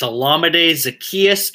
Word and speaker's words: Olamide Zaccheaus: It's Olamide Zaccheaus, and he deserves better. And this, all my sleeper guys, Marvin Hall, Olamide Zaccheaus, you're all breaking my heart right now.